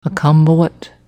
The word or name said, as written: Ackambowet